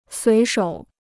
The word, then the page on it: 随手: suí shǒu